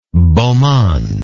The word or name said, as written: beau monde